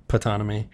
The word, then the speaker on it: Ptonomy: Ptonomy.